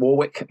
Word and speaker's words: Warwick: Or Warwick, I suppose, Dionne Warwick, if you know who that's, Warwick, we pronounce it, which is right bang in the middle of England.